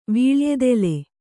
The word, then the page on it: ವೀಳೆಯದೆಲೆ: vīḷeyadele